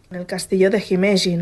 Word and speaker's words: Himeji: uno de los castillos ah, más famosos de Japón es el castillo de Himeji.